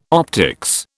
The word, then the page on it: optics: British English